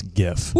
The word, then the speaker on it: GIF: Yeah, I forget it's Jif because that's wrong, because that's peanut butter. It's Gif. – Woo! – It's a hard G.